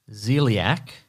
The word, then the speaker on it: Xzyliac: The first person's name is XZYLIAC, which I'm going to pronounce as Zeliak.